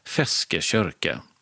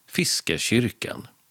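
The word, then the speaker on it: Feskekôrka: Och det var ju varken räktrålan eller silltunnan utan fiskekyrka. Eller med mer normalt svenskt uttal fiskekyrkan.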